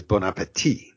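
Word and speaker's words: bon appétit: Well, what everybody says and what they ought to be saying is bon appetit. No T pronounced at the end.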